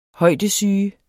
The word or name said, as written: bjergsyge